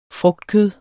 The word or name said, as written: frugtkød